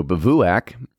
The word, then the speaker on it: Bivouac: So Bivouac, and that's exactly how it's pronounced is what it says on here,